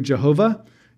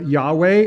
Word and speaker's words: Yahweh: So Lord, just to remind you, when it's all capital letters, L-O-R-D, the ancient pronunciation of this is typically Jehovah. You might have heard that. Or Yahweh is the more modern way of pronouncing this.